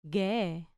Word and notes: ghe: grec

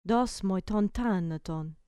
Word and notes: dos moi ton thanaton: grec

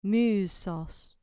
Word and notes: musos: grec

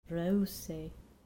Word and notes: reusei: grec

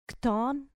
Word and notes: chton: grec

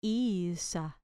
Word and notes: isa: grec

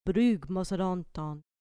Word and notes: brugmos odonton: grec

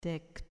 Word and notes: dek: grec

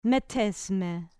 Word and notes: metes me: grec

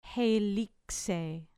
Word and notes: heilixei: grec

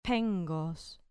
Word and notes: phengos: grec

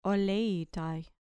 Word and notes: oleitai: grec

